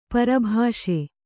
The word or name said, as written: ಪರಿಭಾಸಿ